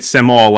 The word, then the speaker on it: Samul: So it's Samul, like Darth Maul.